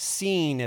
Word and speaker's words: Sin: It has nothing to do with the English word that you know, sin, S-I-N, is pronounced seen.